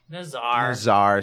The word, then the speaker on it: Nazar: So as one of these mantis creatures, a number of them are approaching Nazar. What is it? – Nazar. – Sorry. I pronounced it like our building manager's name.